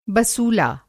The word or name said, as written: बसूला